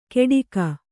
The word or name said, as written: ಕೇಡಿಗ